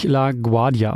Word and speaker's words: La Guardia: Ja, also er spricht sich La Guardia aus. Also man hat so eher so ein Gw. – Guardia und nicht Guardia. – Genau. – Weil die Hinweisgeberin hat uns ja geschrieben, dass das eben nicht so basiert auf dem Französischen, sondern auf dem Italienischen. Und deswegen spricht man halt das U auch noch aus.